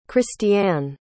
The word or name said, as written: christiane